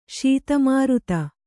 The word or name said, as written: ಶೀತಮಾರುತ